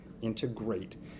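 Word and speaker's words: Integr8: And for those listening, remember, Automation Alley's Industry 4.0 conference, Integrate, that's spelled I-N-T-E-G-R and the number 8, Integrate, will take place November 9th in Detroit.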